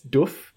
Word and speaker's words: Doof: I realize I've been pronouncing it very Australian as Doof. – Yeah, me too. – Doof, as they say it in the US.